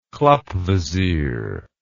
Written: klappvisier

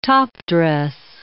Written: top-dress

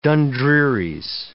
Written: dundrearies

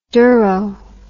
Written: dero

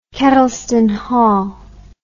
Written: kedleston hall

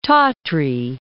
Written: Tatry

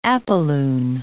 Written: apolune

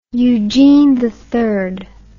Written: eugene iii

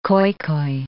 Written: Khoikhoi

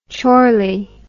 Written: chorley

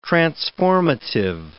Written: transformative

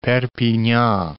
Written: Perpignan

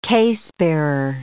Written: casebearer

